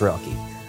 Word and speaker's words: Relke: Hi, my name is KJ Relke. You've probably seen my name pop up in the lower left of the corner and been like, how do you say his last name? It's pronounced Relke.